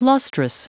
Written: lustrous

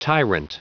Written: tyrant

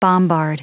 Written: bombard

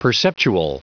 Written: perceptual

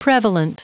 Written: prevalent

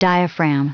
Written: diaphragm

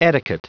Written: etiquette

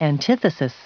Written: antithesis

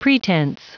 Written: pretence

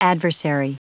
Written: adversary

